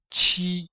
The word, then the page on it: qi: qi1